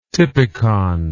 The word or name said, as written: typicon